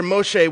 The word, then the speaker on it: Moshe: It's ablaze, according to the story, with somehow it's not being consumed, which is where Moshe, which would be his Hebrew pronunciation of his name, has his first life-altering encounter with Yahweh.